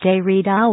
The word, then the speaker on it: Derrida: Even as a teenager, Mr. Derrida, the name is pronounced Derrida, was a voracious reader whose eclectic interests embrace the philosophers Jean-Jacques Rousseau and Friedrich Nietzsche, Albert Camus, and the poet Paul Valéry.